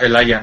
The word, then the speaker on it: Elijah: se escribe Elijah. – Sí, pero se pronuncia Elijah, ¿no? – Elijah, ah, ok.